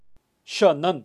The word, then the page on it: xanan: Kaqchikel